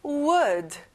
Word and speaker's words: would: Would. L 발음은 안 나요.